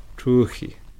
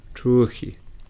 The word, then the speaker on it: truhi: So for day, truhi. – Truhi. – Now try again. Truhi. – Truhi. – Try it with your tongue a little bit further back in your throat.